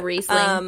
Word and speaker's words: Riesling: I am actually drinking some Riesling. I'm pretty sure that's how you pronounce it. White wine. – Yeah, Riesling.